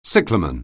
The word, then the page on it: cyclamen: anglais